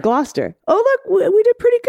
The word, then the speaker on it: Gloucester: pronounce the last three with a st instead of a stir on the end. Glister, Worcester.